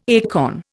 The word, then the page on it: acorn: Indian